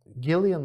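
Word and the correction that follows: Cillian: mı okunuyordu? Gillian Murphy mi? Nasıl Hayır. – Okunuyordu? – Killian. – Ha, Killian Murphy.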